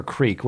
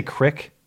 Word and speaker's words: creek: Because people who say crick... – are white trash. – Yeah, that's nonsense. When do two E's make a short I sound? – I know, right?